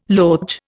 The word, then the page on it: loge: Indian